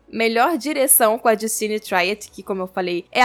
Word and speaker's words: Triet: É, a Justine Triet é francesa, então muito provavelmente a pronúncia é Triet mesmo.